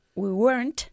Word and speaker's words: weren't: Weren't, not weren't. Weren't. – Sorry. – Una sola sílaba, venga. – We weren't.